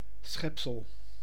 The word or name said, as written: être